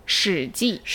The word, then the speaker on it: 史记: 史记。